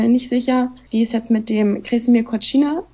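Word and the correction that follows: Kozina: der so ausgesprochen, Cousina heißt er, ja, genau. Krešimir Cousina.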